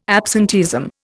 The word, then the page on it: absenteeism: Indian